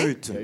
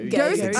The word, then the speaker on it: Goethe: Est-ce que je ah, prononce bien? Goethe. Goethe. Ah, je savais que c'est allemand, c'est ça. Oui, c'est allemand. Pardonnez-moi.